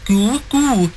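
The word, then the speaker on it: Goo Goo Dolls: Iris by the Go-Go Dolls. I actually thought it was Goo Goo when I was a kid. – It is Goo Goo? It's two O's. Goo Goo.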